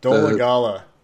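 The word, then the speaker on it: Dolegala: But point being is that if Dalladega looks like the... – Dolagala. – Thank you. Doesn't matter when he earns his name, I'll say it correctly.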